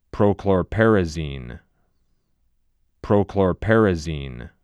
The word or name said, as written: Prochlorperazine